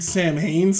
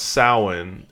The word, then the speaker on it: Samhain: And apparently it's pronounced Samhain, it's just spelled that way, so they're...